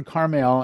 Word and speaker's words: Carmel: And he shall feed on Carmel and Bashan. By the way, that's how you pronounce it. I've been in Israel. I've heard how they pronounce those words.